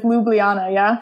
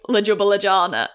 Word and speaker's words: Ljubljana: It made me really laugh because you said it wrong either way, but it's Ljubljana. Yeah. – So that is like a classic, like, Ljubljana.